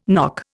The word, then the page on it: nock: Indian